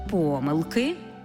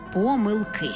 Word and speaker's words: помилки: І до того ж маємо варіантне наголошування цього слова. Бо якщо йдеться про дві, три, чотири, тоді помилки. Якщо ж більше чотирьох, наголос падає на останній склад – помилки.